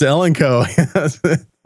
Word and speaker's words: Elenco: You know, I just knew I had to scroll down here because I just knew you were mispronouncing Elenco, which is a amateur radio manufacturer And but it is. It's Elenco. So. – It's Elenco.